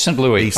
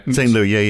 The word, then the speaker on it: St. Louis: from St. So is it St. Louis or St. Louis? I'm never quite sure of that. Which one? How do you pronounce it? Anyway, it's St. Louis, I think. – St. Louis, yeah. So from the St. Louis Fed,